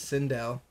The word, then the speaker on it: Sindel: Next character on our list is Sindel. I think I'm saying that right. S-I-N-D-E-L, Sindel, was confirmed to be the forthcoming DLC for Marvel Kombat 11 at the same time as Nightwolf.